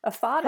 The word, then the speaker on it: aphotic: A P H O T I C. – Aphotic. How would you pronounce that? Aphotic? – Aphotic, okay.